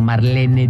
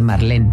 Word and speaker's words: Marlene: como Marlene Dietrich, por ejemplo, que todo el mundo dice Marlene, pero es Marlene.